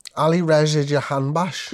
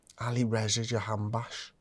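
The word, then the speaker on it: Alireza Jahanbakhsh: No, I know that's how you say it because you played for Brighton then. – Ali Reza Jahan bash.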